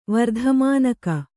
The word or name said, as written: ವರ್ಧಮಾನಕ